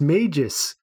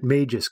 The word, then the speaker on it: Magus: It should be pronounced Magus." And this is a person who has listened to the audiobooks of The Dark Tower, so I'll take that on some certain authority that perhaps Magus is the correct way to say it, if I am understanding the phonetic spelling on Twitter, which, knowing me, I could be screwing that up as well. But I'm starting to pronounce it Magus going forward.